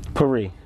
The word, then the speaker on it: Perry: Perry. – Like Marie with a P.